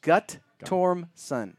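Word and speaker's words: Guttormson: It's three syllables. Gut-torm-son. – Gut-torm-son. – Gut-torm-son. – Just like it's spelled.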